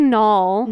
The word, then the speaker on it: Knoll: I want to say Jessica Knoll, Knoll, K-N-O-L-L. – I would say Knoll. – Knoll, just a silent K.